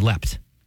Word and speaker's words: leapt: I've always pronounced it as leapt. When you have the T, but I would pronounce it as leapt, and if it was ED, I would say leaped.